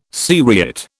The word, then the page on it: seriate: British English